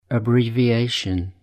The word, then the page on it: abbreviation: British English